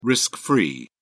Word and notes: risk-free: British English

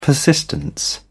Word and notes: persistence: British English

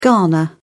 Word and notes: ghana: British English

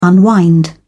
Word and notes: unwind: British English

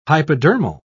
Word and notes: hypodermal: British English

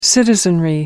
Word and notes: citizenry: American English

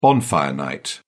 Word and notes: bonfire night: British English

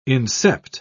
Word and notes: incept: British English

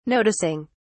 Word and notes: noticing: British English

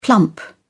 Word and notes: plump: British English

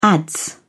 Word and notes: adze: British English

